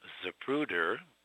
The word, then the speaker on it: Zapruder: And she pronounced it Zapruder, precisely, as it was spelled when I then picked up the Dallas phone book and ran my finger down the Z's, and there it was.